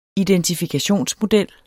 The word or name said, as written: identifikationsmodel